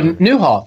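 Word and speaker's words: Nieuwhof: How do you say his last name? Do y'all know? Nyhoff. – Oh, Newhoff. It's Carrie Newhoff.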